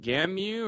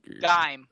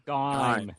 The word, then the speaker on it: Gaim: What is the final on the pronunciation? Is it Gamu or... – Gaim. – Gaim. – Okay, G-A-I-M. No U. – So Gaim. Have you ever heard of Elgaim? – No. – Yeah. – Rhymes with lime.